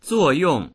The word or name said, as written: zuo4yong4